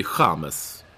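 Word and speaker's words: James: Men däremot så säger vi Chamez, för att så uttalar man det i Colombia, eller hur?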